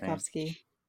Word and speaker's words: Tchaikovsky: Like, she's playing Tchaikovsky. Is that how you pronounce that man's name? – Tchaikovsky.